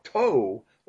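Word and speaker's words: toe (τῷ): And in the NA27, you'll see that the article changes from a hoe, which is an O with an apostrophe over it, sort of, to a toe or a T-O, and not the Omicron O, but the Omega O, the long O. So it's toe.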